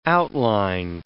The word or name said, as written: outline